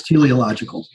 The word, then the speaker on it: teleological: Maybe I'm not even pronouncing it right. Maybe it's teleological. – Yeah. – It's teleological.